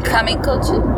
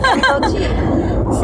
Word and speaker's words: Kamikouchi: So after that, we went to Takayama and on the way stopped at, somebody say it correctly. – Kamikochi? – Yes. – Is that what you're talking about? Okay. – Yeah, you're right. Kamikochi.